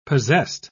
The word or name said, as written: possessed